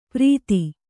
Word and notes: ಪರಿಧಿ: pari dhi

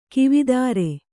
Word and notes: ಕವಿತರು: Kannada

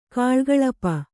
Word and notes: ಕಲಾಕಲಾಪ: kalākalāpa

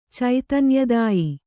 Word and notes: ಚೈತನ್ಯದಾಯಿ: Kannada

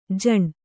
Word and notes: ಝಣು: jhaṇu; kannada

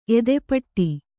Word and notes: ಎದೆಪಟ್ಟಿ: edepaṭṭi

